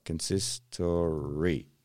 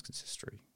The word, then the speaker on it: consistory: How do I pronounce this? – Consistory. – There you go. I said it right. I knew it.